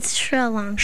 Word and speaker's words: Sri Lanka: I was saying Sri Lanka, and my daughter here said it's Sri Lanka.